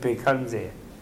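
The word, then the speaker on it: Bhaiṣajya: And the person who's sick, they say, Om Baishajya Baishajya Maha Baishajya, although the Tibetans pronounce it Pekanze.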